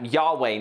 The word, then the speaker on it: Yahweh: It was YHWH. We kind of pronounce that Yahweh now, but they didn't pronounce it back then.